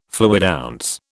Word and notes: fluidounce: British English

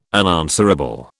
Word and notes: unanswerable: British English

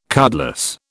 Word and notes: cutlass: British English

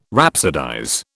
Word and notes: rhapsodize: British English

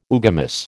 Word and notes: oogamous: American English

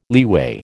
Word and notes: leeway: American English

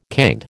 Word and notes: canned: American English

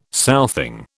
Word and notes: southing: British English

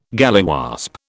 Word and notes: galliwasp: British English

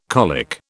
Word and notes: colic: British English

